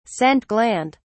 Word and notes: scent gland: British English

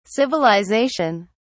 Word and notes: civilisation: British English

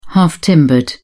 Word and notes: half-timbered: British English

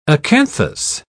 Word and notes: acanthus: British English

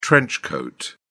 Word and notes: trench coat: British English

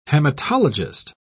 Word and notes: haematologist: British English

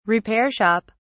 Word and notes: repair shop: British English

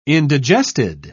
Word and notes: indigested: British English

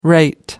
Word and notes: rate: American English

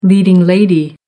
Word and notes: leading lady: American English

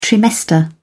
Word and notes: trimester: British English

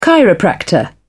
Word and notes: chiropractor: British English

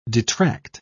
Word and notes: detract: British English

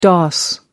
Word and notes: dos: American English

